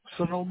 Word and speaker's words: Sunil: Sunil Maharaj, the same person you just called.